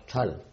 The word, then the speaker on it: Thal: Thal? – Thal.